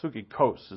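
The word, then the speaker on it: Psychikos (ψυχικός): Psychikos. Psychikos is how he's pronounced it.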